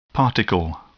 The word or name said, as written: particle